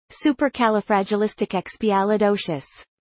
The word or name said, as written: supercalifragilisticexpialidocious